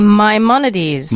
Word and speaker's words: Maimonides: That would be Maimonides.